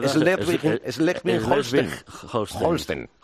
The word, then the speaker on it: Schleswig-Holstein: en concreto en Schleswig-Holstein. Yo no sé si lo he pronunciado correctamente, porque mi hijo habla muy bien alemán con 14 años, pero yo, francamente, mal. – No, Schleswig-Holstein.